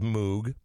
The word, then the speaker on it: Moog: I know it's spelled Moog, but it's pronounced Moog, and these things are important.